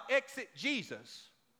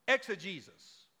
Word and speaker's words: exegesis: Not exit Jesus, exegesis.